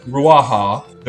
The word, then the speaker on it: Ruaha: Ruaha.